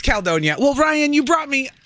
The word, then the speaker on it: Caledonia: I say Caledonia with 2 syllables, you say it with 3.